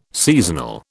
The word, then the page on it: seasonal: British English